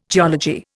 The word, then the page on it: geology: Indian